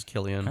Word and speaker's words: Cillian: Which I found out that's how you pronounce his name, because I was pronouncing Cillian. – It's not Cillian. – It's Cillian.